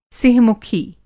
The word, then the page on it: सिंह-मुखी: Hindi